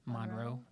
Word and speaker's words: Monroe: Is it Monroe? Is that how we say her name? – Monroe? – Okay, making sure.